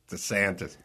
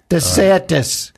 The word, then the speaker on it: DeSantis: DeSantis. – DeSantis.